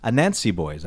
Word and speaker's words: Anansi Boys: Anansi Boys.